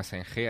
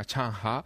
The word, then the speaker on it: Zheng He: Allí no se llama Zheng He, allí se llama Chang He, que es...